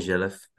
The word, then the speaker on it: Zhelev: My name is Kostadin Zhelev.